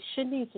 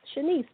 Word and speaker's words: Shaniece: It's Shanice, and it's spelled just like it's pronounced. My aunt named me, so it's S-H-A-N-I-E-C-E, Shanice, because I'm her first niece. Last name is Bennett, B-E-N-N-E-T-T.